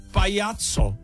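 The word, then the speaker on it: Payaco: Píše se to Pa-Y a co a čte se to Pajaco. – Pajaco. – V závorce napsáno opravdu s C.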